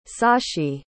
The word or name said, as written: sashi